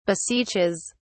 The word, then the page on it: beseeches: British English